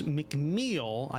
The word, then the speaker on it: McMeel: we're playing Zweihander, a grim and perilous RPG published by Andrews McMeel. I did double-check that, so that is how you pronounce it.